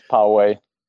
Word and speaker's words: Poway: Is that how you pronounce Poway? I'm not quite sure about that. – Okay. Yeah, it's Poway.